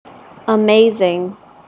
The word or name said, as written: amazing